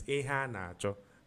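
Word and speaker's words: Iheanacho: His correct pronunciation is Ihanacho.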